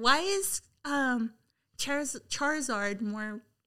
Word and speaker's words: Charizard: Wait, what's the correct pronunciation? Charizard or Charizard? Charizard. Like Charmeleon.